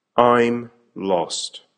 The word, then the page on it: I'm lost: American English